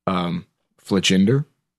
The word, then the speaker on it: Fletchinder: You have to first either find a Fletchling or a Fletchinder. That's how you pronounce it. – Yeah, because I think it's supposed to be like off Tinder. – Cinder. – Cinder, there we go.